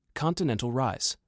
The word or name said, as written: Continental Rise